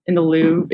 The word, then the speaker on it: Louvre: Louvre.